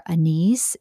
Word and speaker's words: anise: You can put in, it's saying you can put in like star anise. Is that how you say it? A-N-I-S-E. Mm-hmm.